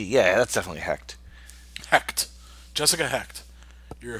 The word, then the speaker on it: Hecht: Oh, how would you pronounce that? H E C H T.